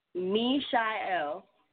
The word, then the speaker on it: MeSha'el: Mishael.